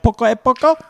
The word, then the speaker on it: Poco a Poco: Did I say that right? Poco a Poco? – You're right. You're right on it.